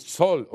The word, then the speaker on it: Saül: Donc, chapitre 8, je vais prononcer Saül, vous m'excusez si ce n'est pas correct. On peut dire Saul aussi, d'après la phonétique française.